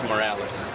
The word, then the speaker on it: Morales: Carlos Morales.